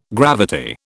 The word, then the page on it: gravity: British English